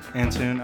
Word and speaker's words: Antoon: How do you say your last name? – Antoon. – I was like Antoine, but I don't see the Twan part. – No, everyone says Anton.